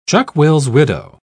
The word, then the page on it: chuck-will's-widow: British English